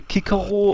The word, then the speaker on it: Cicero: Also Cicero wird jetzt Kikero ausgesprochen und Cäsar wird Kaiser ausgesprochen. Ist wirklich wahr.